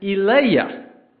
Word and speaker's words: Elijah: And in English, we say Elijah. In their language, they would have pronounced it Eliah.